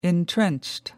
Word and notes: entrenched: American English

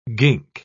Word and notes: gink: British English

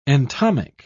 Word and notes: entomic: British English

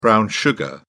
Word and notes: brown sugar: British English

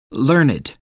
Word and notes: learned: American English

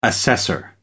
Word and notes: assessor: American English

